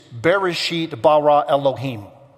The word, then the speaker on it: Bereshit bara Elohim: It is Bereshit bara Elohim. That's how you say it in Hebrew.